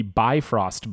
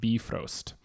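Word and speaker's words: Bifröst: Okay, so, well, there's an umlaut over the O, so it's probably pronounced Bifrost.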